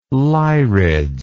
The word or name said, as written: lyrids